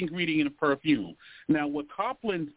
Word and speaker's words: copulins: It's spelled C-O-P-U-L-I-N-S, Coplins.